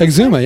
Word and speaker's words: Exuma: Exuma? Did I say it right? – Exuma, yeah.